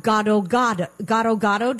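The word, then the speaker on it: gado-gado: You have gado gado. Do I say it properly? – Yeah. – The peanut dressing. – That's right. Yes.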